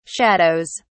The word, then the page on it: shadows: British English